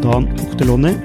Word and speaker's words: Dan Ochterlony: Idag ska vi snacka med investor Dan Ochterlony. Är det riktigt uttalat? – Ja, det är korrekt.